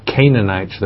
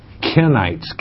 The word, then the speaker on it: Kenites: And then you said, and the Canaanites, and you spell it with a C, it's not Canaanites that we're saying, Kenites, K-E-N-I-T-S, are of the tribe of Cain.